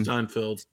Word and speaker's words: Steinfeld: I think Hailee Stanfield did a I'm pronouncing her name right. Um, Steinfeld. Steinfeld. – Uh, Stein, like beer Steinfeld, I think is how it's it.